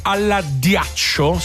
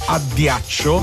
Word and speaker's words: addiaccio: Dormire alla ghiaccio, si dice addiaccio.